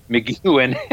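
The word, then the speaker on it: McGuinn: Yeah, McGinn, or what? Geez, I'm going to mispronounce it. – Yep. – McGuin.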